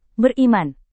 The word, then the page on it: beriman: Indonesian